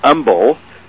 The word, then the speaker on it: Humble: I mean, Humble Oil, or as they like to call it in Texas, Humble, without pronouncing the H, is a company now part of one of the Exxon, actually.